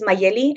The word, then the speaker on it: Magely: So again, I know it's like really confusing, but my name is Magely Martinez. For those that have a hard time pronouncing in English, it's Magely in Spanish.